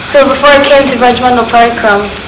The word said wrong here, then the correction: Parikrama: Parikrama. Could you also say Parikrama?